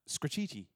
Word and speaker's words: Scratchety: Scratchety, as we pronounced it, probably is pronounced to rhyme with graffiti, she said. So it's scratchity.